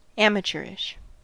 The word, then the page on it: amateurish: American English